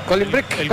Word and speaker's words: Cooling Break: Cooling Break. Es así, se dice así, ¿no? – Cooling Break.